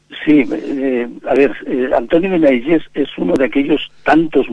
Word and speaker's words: Benaiges: Bueno, en catalán es Benaiges.